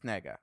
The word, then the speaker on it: Negga: The lead actress, Ruth Negga, is that how you say her name? – Ruth Negga.